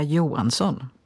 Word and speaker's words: Johansson: Ještě kdyby se to vyslovilo švédsky, tak je to Petra Johansson.